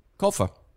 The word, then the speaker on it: Colfer: Colfer.